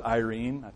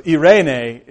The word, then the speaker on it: eirene: At first I thought it was Irene. I don't think it's Irene. So eirene is the Greek word, and that's a translation from the Hebrew, meaning all that shalom means, but much more.